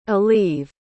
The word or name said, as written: aleve